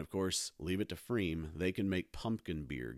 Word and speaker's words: Freiem: There's a brewery called Freem. It's spelled Freiem.